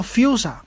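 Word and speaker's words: Fuchsia: 好，查到了，它叫 Fusa 啊，这么一个英语啊，终于拼出来了。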